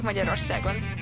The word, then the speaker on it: Magyarország: Üdvözlünk Magyarországon. Porque en Hungría no decimos Hungría o Hungary, Ungar o algo así, sino Magyarország.